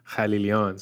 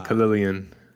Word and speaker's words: Khalilian: And so the Farsi way of saying my last name is Khalilian. So I gave you the American way of saying my name, Khalilian.